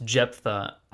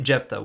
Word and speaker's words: Jephthah: But, yeah, so Judges 11, it's about a guy named, I think it's pronounced Jephthah. That's how I'd pronounce it. It's like J-E-P-H-T-H-A-H.